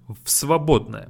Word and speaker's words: в свободное: В свободное. But we read it as one word. В свободное. And в becomes ф. В свободное. В свободное. For ease of our pronunciation.